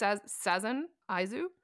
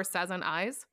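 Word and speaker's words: 3×3 Eyes: It's pronounced Sazen Aizu, so, or Sazen Eyes. Because Sazen is how you say 3x3 in the Japanese multiplication table.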